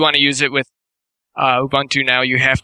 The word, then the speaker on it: Ubuntu: It's not Ubuntu. It's Ubuntu. So you said Linux compatibility.